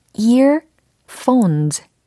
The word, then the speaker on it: earphones: 예, 이어폰은 영어로 어떻게 발음하면 정확해질까요? 그죠? earphones, earphones, earphones, earphones 라고 하시면 되겠습니다. 일단, 어, 귀에 해당하는 ear 부분 발음 요거 점검하시고 ear 이게 아니고 ear이라고 하셔야 되고 그리고 폰이 아니고 phone이라고 하셔야 되고 그리고 earphones, earphones라고 해서 끝부분의 복수형 어미까지 처리를 해본 겁니다.